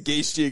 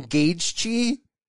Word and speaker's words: Gaethje: But he says I'm doing Justin Gaethje. Gaethje. He says I'm doing Gaethje.